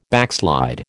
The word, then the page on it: backslide: American English